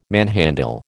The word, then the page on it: manhandle: American English